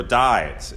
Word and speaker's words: Dives: In the Middle Ages, there's a lot of paintings about Lazarus and Dives, or Dives, it's sometimes pronounced.